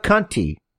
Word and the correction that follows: Conti: Tainara Conchi. – Tainara Conti. – Conchi. Yes. – It's spelled wrong. – No, it's Brazilian Portuguese.